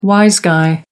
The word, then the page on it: wise guy: American English